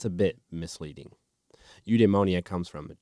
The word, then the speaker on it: eudaimonia: So this fulfillment and happiness and immediate payoff and like this drive and everything led me to a word, and it's Greek. And let's see if I can pronounce it right. It is Greek, and it is called eudaimonia. That's it. Yep.